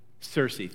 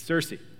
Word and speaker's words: Circe: Ulysses was warned of the sirens by somebody named, I don't know how to pronounce this, somebody just said it. Circe, thank you. Okay. Any other pronunciation questions, go to whoever that was that just spoke. Is it you? Okay, thanks. Circe, I knew that.